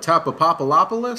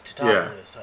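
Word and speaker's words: Tatopoulos: They're like, Tapopopoulos? – This is Mr. Tapopoulos. I was like, it's Tapopoulos, yeah.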